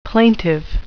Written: plaintive

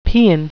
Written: paean

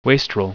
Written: wastrel

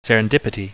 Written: serendipity

